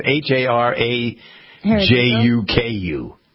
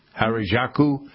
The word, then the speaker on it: Harajuku: How do you, it's spelled H A R A J U K U. Harajuku.